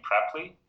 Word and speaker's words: Preply: Actually, we call this company Preply, so the right pronunciation would be Preply.